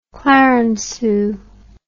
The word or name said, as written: clarenceux